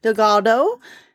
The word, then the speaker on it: Delgado: But the way her name is pronounced is actually like banana, so Alana Delgado.